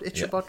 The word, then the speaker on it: Ichabod: Is that how you pronounce it? Ichabod, Ichabod yeah.